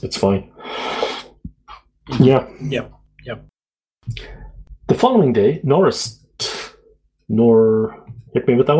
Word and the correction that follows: Norrist: I think he pronounces it Norist. – Norist. – Okay. Norrist.